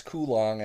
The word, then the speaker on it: Coulomb: It's Coulomb, actually. Accent okay. On the first syllable.